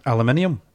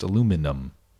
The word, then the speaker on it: aluminium: There's no inium there. It's just aluminum. – No, aluminium. – But I don't think there's an ium. – It's aluminium, Mike. – Because it's spelled U-M, not I-U-M.